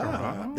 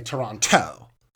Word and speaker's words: Toronto: Tanya Sam, who pronounces both T's in Toronto. – Well, you know what? I noticed something with that. – Uh-huh. – Because when she's in confessional, she'll say Toronto.